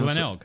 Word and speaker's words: Glenelg: How do you pronounce Glenelg.